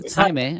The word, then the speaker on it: Jaime: Like people, when they see me and I tell them my name is Jaime, they're like, oh, Jamie, Jamie. And I'm like, no, it's Jaime. I didn't just create a fancy pronunciation for Jamie.